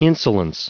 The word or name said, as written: insolence